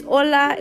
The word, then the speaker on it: hola: The H is silent in Spanish. So it's not ¿Qué haces? It's not hola. It's hola, it's ¿Qué haces?